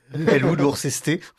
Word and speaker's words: Worcester: Elwood Worcester, enfin, ouais, comme la sauce qui va sur le, oui, c'est...